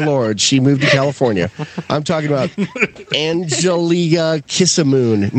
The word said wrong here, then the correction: Anjali Ram Kissoon: No, what's her name, Lou? How do you say it? – Anjali Ram Kissoon.